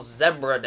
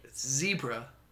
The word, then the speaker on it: zebra: The other day was National Zebra Day. – Why'd you say it like that? – That's how you say it. – Zebra.